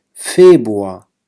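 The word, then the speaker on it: Februar: لاحظوا هون شهر فيبروار، هاي الآية وهاي الآية تقريبا ما لفظتها أبدا. فيبروار يعني تقريبا F A B U A، فيبروار. الآية هاي والآية هاي لفظ خفيف إلى تقريبا لا تلفظ.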